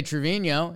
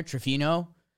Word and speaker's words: Trevino: Um, uh, and if you say his name with, uh, depending how you say it at the end, you can kind of throw an H in there, Trevino. Um, Trevino.